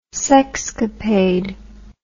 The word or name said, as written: sexcapade